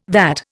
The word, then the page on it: VAT: Indian